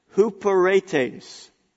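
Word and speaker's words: huperetes (ὑπηρέτης): It's not pronounced like it looks like. It's pronounced huperetes.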